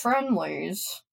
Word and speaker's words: Friendly's: when I was a waitress at a large chain restaurant that kind of shut down in Ohio, my home state, it rhymes with Schmenley's. – I don't know what that is. – Really? – No. – Oh, okay. It's Friendly's.